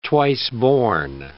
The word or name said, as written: twice-born